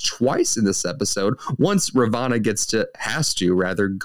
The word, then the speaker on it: Ravonna: Ravonna.